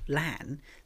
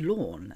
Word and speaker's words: LAN: It's not LAN, it's lawn. And they were saying it like LAWN, like mowing your lawn.